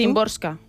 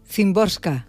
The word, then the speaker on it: Szymborska: Szymborska. – Szymborska.